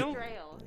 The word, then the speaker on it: Strahle: Did I get that? – Not even close. – You do Strail. Butcher the English language. – Strail. Yes. – It's Strail? – Yeah. – I could have sat here for an hour, never would have guessed that. – How do you spell that? – Strahle. – Yeah, you got it. So I think the German version is something closer to Strahl.